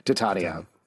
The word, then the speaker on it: Titania: Titania, whatever it is. – Titania is the traditional Shakespearean pronunciation the character from A Midsummer Night's Dream, classically trained actor, for those of you new to the podcast.